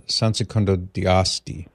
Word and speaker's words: San Secondo di Asti: So I think I mispronounced the name of the church, by the way, John. It's San Secondo di Asti. A-S-T-I.